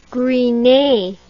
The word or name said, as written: Gris Nez